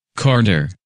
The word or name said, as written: Carter